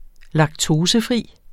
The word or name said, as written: laktosefri